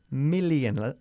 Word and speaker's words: million: Million. La doble L ahí se pronuncia igual que una sola.